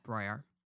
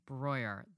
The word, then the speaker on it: Breuer: You can quit now, or you can walk to 75th and Madison to the Met Broyer. – I'm sorry, could you repeat that one more time for everyone out there who is still pronouncing this museum's name incorrectly? – Yeah, you heard me. Breuer, the Met's second location at the old Whitney building.